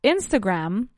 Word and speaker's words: Instagram: We say Instagram, el acento o el stress, ¿no? La acentuación recae sobre la primera sílaba, Instagram, right?